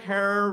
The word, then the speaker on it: Herer: he is at a, our hero, Jack Herer, Rhymes with Terror, is at a rally protesting the inauguration of one Ronald Reagan.